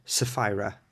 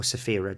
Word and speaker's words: Sapphira: Sapphira, or Sapphira, depending on what pronunciation you want to take.